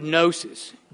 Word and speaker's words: gnosis: But Gnosticism comes from the Greek word gnosis, G-N-O-S-I-S. Now, it shouldn't surprise us that the G is silent, because it's that Greek word from which we get knowledge, or the word know. Now, we have substituted K for G.